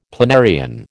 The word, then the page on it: planarian: American English